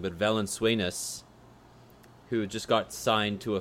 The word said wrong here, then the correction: Valanciunas: I always said it wrong during the season. Valenzuelas. Valanciunas.